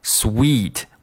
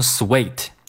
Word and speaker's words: sweet: 然后 Phil 这个时候继续说 sweet，那这个 sweet 你注意了，两个 e 哈，两个 double e 发的是一个 e 长元音 e， sweet 不要读成 sweet，我听到很多同学会读成 sweet，那它的发音是错的，应该是一个长元音 e。